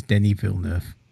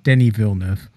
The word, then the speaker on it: Denis Villeneuve: We can never pronounce his name, but his name is Denis Villeneuve. There you go. We're going to get it right now. Denis Villeneuve.